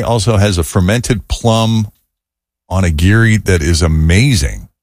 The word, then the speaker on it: onigiri: Kate says, yes, onigiri.